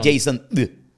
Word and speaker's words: Jasond: Por eso mi nombre se escribe con D al final. – Y no es Jason D.